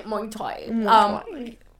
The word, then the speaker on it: Muay Thai: But you have your Muay Thai. Is that how you say it? Muay Thai? – Muay Thai.